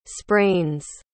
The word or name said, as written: sprains